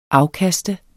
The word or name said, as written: afkast